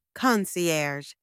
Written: concierge

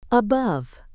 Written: above

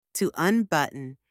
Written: to unbutton

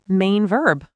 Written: main verb

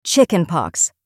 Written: chickenpox

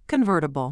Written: convertible

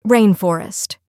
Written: rainforest

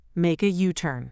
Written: make a U-turn

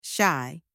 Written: shy

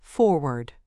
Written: forward

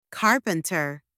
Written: carpenter

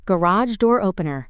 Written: garage door opener

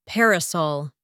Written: parasol

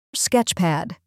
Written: sketchpad

